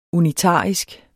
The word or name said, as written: unitarisk